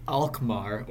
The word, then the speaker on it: Alkmaar: We failed to score against AZ Alkmaar, or however you pronounce that, a Dutch team. At least you pronounce Alkmaar right, and you pronounce Rochdale wrong, which is surprising.